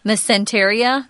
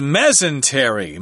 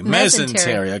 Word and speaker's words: mesentery: And how are you pronouncing this? Mesenteria? – I did look it up before because it's actually something that the body has already, but nowadays they are identifying it as an actual organ. – Uh-huh. Let me see what the pronunciation guide here says.